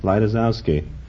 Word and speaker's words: Lejtizouski: Originally, it was spelled L-E-J-T-I-Z-O-U-S-K-I. – How did you pronounce it then? – Laitisowski.